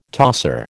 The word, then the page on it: tosser: American English